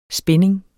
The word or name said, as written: spinning®